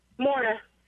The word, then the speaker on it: Maura: Am I saying your name correctly? Is it Mara? – Maura. – Okay, so who's like going to win this? – But with an M.